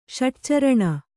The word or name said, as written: ಷಟ್ಚರಣ